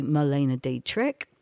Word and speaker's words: Marlene Dietrich: And in terms of, as well, this song in German was covered by Marlene Dietrich.